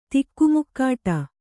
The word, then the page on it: ತಿಕ್ಕುಮುಕ್ಕಾಟ: tikku mukkāṭa